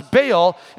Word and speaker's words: Baal: And it's an altar to Baal, or sometimes you'll hear it pronounced as Baal, and then there's an Asherah pole that is right beside it.